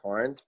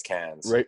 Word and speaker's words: Cairns: So it's like, it's spelled like Cairns. Like, I would always say Cairns, but how you say it's Cairns.